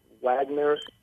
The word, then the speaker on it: Wagner: Kurt Wagner. He is German, so it's pronounced Kurt Wagner with a V.